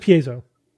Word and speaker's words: piezo: I believe it's piezo, not piezo. I think you're pronouncing it really? Italian for some reason. Yeah, it's just piezo.